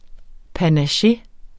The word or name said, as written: panache